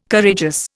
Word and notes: Courageous: Indian

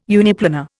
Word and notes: uniplanar: Indian

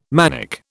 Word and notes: manic: British English

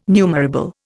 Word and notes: numerable: Indian

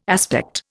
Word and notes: aspect: Indian

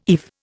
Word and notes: eve: Indian

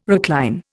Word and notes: recline: Indian

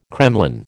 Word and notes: kremlin: American English

